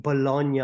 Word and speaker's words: Bologna: Because you don't pronounce the G in Bologna or Bolognese.